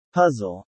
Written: puzzle

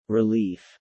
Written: Relief

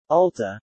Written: Ulta

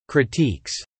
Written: critiques

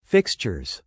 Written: fixtures

